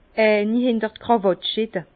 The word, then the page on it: eini hinter d'Kràwatt schitte: Bas Rhin